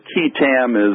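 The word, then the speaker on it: qui tam: Now, the first thing I need to do is to teach you and how to pronounce the name of these lawsuits, and it is Keatam. – Keatam.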